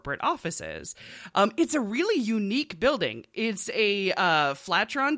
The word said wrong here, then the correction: Flatiron: design, or Flatrion. I can never remember quite how you say it. It's basically, if you think of the one, Flatiron. That's the word. It's a Flatiron building.